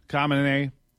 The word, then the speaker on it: Khamenei: But Ayatollah Ali Khamenei, I don't know. How do you say the guy's name? Khamenei, there you go.